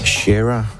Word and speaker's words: Shara: Shara, som det i virkeligheden udtales. Altså Shara Nelson, jeg sagde jo Sarah. Det er fordi, jeg lige sniger sig et H ind.